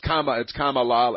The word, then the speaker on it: Kamala: It's Kamala, except when she mispronounces it. Then it's Kamala.